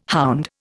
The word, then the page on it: hound: Indian